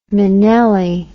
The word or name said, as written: minnelli